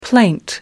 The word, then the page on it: plaint: American English